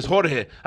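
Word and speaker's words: Jorge Linares: Yo, George Linares, Jorge. That's how you say it in Spanish, you know what I'm saying?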